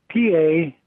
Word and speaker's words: Paducah: In the letter, which is in the Missouri Historical Society, right here, the script changes, and he wrote in capital letters, P-A-D-U-C-A-H, to help in the correct pronunciation.